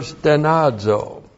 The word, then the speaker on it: stenazo: And then the verb stenazo. S-T-E-N-A-Z, long O. Stenazo.